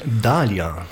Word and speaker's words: Dahlia: Das ist Alessandro Liga, Dahila heißt der Track, wenn ich es richtig ausspreche. – Nein, Dahlia. – Oh, ja. – Dahlia, und das ist die Blume.